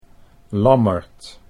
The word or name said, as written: Lammert